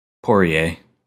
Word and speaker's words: Poirier: Excuse me, I said it wrong. Dustin Poirier? – Poirier.